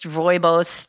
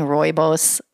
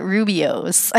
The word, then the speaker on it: rooibos: And can I just say I've been saying rooibos wrong all these years. I call it Rubio's.